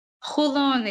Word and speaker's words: Holon: Holon, it's H-O-L-O-N, Holon.